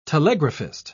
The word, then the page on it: telegraphist: British English